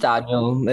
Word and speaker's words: Danyo: Yeah, just Danyo. It's like either it's spelled differently, just like my normal name and then my kind of stage name, if you would. But yeah, no, it's just Daniel, just as you would say it.